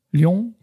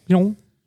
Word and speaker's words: Lyon: ¿Cómo se dice Lyon en francés? Lyon. ¿O? Lyon. Más, o sea, más rápido. – Lyon.